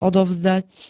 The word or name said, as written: odovzdať